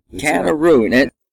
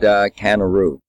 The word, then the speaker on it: CannaRoo: CannaRoo. – CannaRoo. I really like the name. Tell us a little bit about your company and CannaRoo.